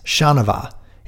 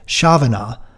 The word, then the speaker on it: Chanavat: Quote, you are reversing the N and V and pronouncing it Chavanaugh, like it rhymes with Kavanaugh.